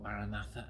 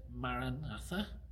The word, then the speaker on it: Maranatha: One version, which sounds pretty much as we would, pronounce it Maranatha. Maranatha means the Lord has come or the Lord is here. But the other, slightly different one is Maranatha. Maranatha. And that's more of a plea.